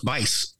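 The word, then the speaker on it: Buice: Dwayne Buse. I've seen this name before. I don't know how you pronounce it, but it's spelled juice, but with a B. – Oh, it's Bice. – Bice.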